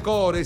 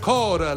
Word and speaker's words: core: Non si dice core, si dice core, la E non si pronuncia.